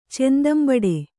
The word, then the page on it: ಚೆಂದಂಬಡೆ: Kannada